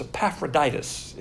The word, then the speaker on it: Epaphroditus: I wouldn't pronounce that word in the break. It's Epaphroditus is the correct pronunciation.